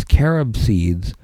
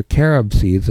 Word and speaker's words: carat: Those are carob seeds, and that became pronounced carrots. Nothing to do with the vegetable carrots.